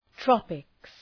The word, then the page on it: tropics: ‘trɒpıks